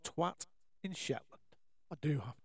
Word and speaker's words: Twatt: Believe it or not, there is a place in Orkney called Twatt. Now, it's spelt T-W-A-double-T, so it's not quite the same as the beloved swear word, but it's close enough, I think.